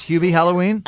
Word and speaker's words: Hubie Halloween: Hubie Halloween? – I love him.